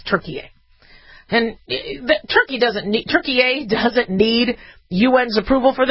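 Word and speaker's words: Türkiye: When you ask if the ambassador to Turkiye is here, that's how you say it.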